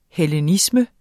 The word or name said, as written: hellenisme